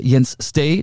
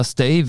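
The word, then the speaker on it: Jens Stage: der Außenverteidiger, der ja eher so einen äh, offensiven Außenverteidiger gibt oder auch äh, Jens Stay, der zwar Stage geschrieben wird, aber Stay wie äh, to stay bleiben, englisch ausgesprochen mit Stage, die waren immer da und haben die Wolfsburger so unter Druck gesetzt.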